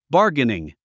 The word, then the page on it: bargaining: bάːrɡəniŋ; バーゲニン